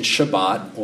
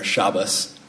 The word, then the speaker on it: Shabbat: The Hebrew verb Sabbath, or the more common pronunciation Shabbat, or for those Lebowski fans out there, Shabbos, in the Ashkenazi pronunciation.